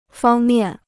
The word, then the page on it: 方面: fāng miàn